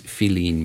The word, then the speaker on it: filín: And it's, in Cuba, they spell it F-I-L-I-N, which is the way it sounds in Spanish, or how it's spelled in Spanish.